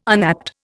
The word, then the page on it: unapt: Indian